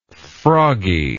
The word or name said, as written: froggy